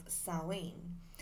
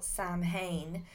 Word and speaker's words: Samhain: So the origins of Halloween lie in the pagan celebration of Samhain, which is actually, it's actually spelt, you may have seen the word around, it's actually spelt Samhain, but it's pronounced Samhain, which is where today's word Halloween has become.